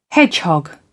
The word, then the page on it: hedgehog: British English